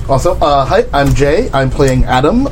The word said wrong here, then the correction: Adem: Adem. Not Adam Adem.